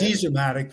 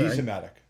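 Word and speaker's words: Disamatic: Disimatic. Is that how they say it? – Dezimatic, they would say. – Dezimatic.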